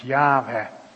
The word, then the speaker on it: Yahweh: Die vier hoofdletters. Die waarschijnlijk uitgesproken worden als Yahweh.